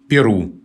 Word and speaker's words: Перу: А Флоренция Леонидовна вспоминает историю названия «Перу», государства в Южной Америке. Она вспоминает, что в течение многих лет употреблялся вариант «Перу» с ударением на первом слоге.